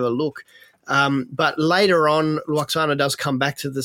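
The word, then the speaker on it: Lwaxana: Luoxana. – Luoxana, yeah.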